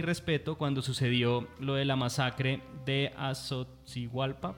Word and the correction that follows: Ayotzinapa: perdón si no lo pronuncio bien. – Ayotzinapa. – ¿Cómo? Perdón. – Ayotzinapa. – Eso, de Ayotzinapa, perdón.